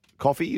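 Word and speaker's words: Coffey: Coffee, is that how you say it? Coffee Sisters? – Yep, there's Ellie Coffee, Billie Jean Coffee, and Ruby Coffee.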